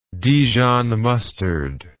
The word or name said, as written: dijon mustard